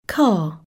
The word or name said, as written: car